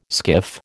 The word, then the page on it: skiff: American English